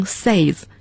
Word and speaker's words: says: 那么我们要注意在说英语的过程中 ,say 是代表普通的动词原形 ,say, 单数第三人称 ,S-A-Y-S, 不能读成 says, 要读成 says, 发小 a 的声音像床 ,bed 那个发音。